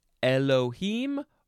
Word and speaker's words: Elohim: Capital E-L-O-H-I-M. You can pronounce it Elohim or Elohim. Elohim. I think the first pronunciation is more accurate. That's why they put it there first. But the second one sounds a bit more like it's spelled Elohim opposed to Elohim.